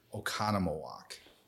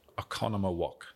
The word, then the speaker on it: Oconomowoc: Okanawoki. – I love it. We have a lot of Native American history in the state of Wisconsin, so it's actually pronounced Oconomowoc. – Oconomowoc.